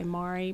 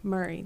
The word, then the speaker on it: Maori: Yeah, and people, and anybody from outside there always will say Maori, but the local people say Murray. They pronounce it M-U-R-R-Y.